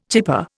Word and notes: chypre: Indian

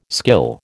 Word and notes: skill: American English

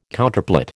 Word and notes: Counterplot: American English

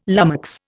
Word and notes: lummox: Indian

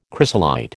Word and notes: chrysolite: American English

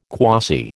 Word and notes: quasi: American English

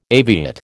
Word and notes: aviate: American English

